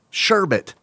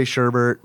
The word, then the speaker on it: sherbet: I normally say Sherbert, but since I'm doing a podcast right now, I figured I'd say it the right way.